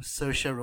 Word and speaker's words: Saoirse: Stars, I'm going to mispronounce her name, Saoirse Ronan.